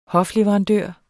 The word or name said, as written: hofleverandør